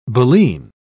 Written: baleen

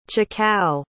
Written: chikao

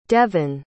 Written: devyn

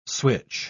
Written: switch

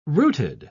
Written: rooted

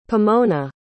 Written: pomona

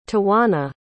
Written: tawana

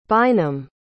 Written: bynum

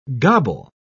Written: gobble

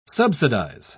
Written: subsidize

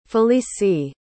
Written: felicie

